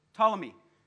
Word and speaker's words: Ptolemy: How do you say that? Ptolemy. So it's a silent P, is it? Okay, I could make a good joke there, but I won't. the silent P.